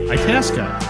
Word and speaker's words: Itasca: Itasca, it rhymes with Alaska.